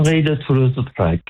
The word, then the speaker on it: Henri de Toulouse-Lautrec: I mean, you do the pronunciation with your proper French accent. – Henri de Toulouse-Lautrec, yes. – Oh, I don't think I did too badly. – Yeah. No, you did very well, actually.